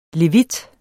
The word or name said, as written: levit